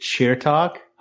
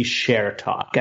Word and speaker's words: Chertok: So this is a great question, because actually when I was growing up, I would actually pronounce it cheer talk. I don't know why I would pronounce it that way, because I don't know if my parents ever said it like that. But then I later learned about the history of it. Mm-hmm. And the most accurate would probably be share talk, as if Cher had a talk show.